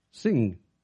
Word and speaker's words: Singh: There was a man in India by the name of, I hope I can pronounce this right, a missionary, Sadhu Sundar Singh. S-I-N-G-H.